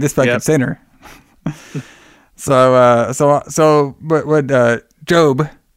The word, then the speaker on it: Job: And then this is when Jode. Am I saying his name right? Jode. – Job. J-O-B. – So it's a Job with the okay. – Yeah, like in the Bible.